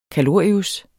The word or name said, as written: kalorius